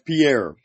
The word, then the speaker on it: Pierre: Prairie? – Oh, yeah, that's Pierre. Pierre, South Dakota. – Pierre. That's what he said. Pierre.